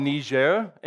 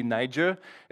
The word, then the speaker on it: Niger: It's called Niger. English pronunciation could be Niger.